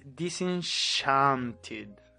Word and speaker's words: Disenchanted: ¿Cómo es la pronunciación de esa weá? – La, busqué, pues, ¿Cómo es? – Disenchanted.